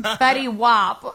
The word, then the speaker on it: Fetty Wap: Fetty Wap, not Wap.